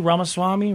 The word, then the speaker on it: Ramaswamy: Ramaswamy, right? – Yeah.